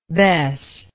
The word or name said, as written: bess